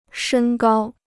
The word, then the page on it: 身高: shēn gāo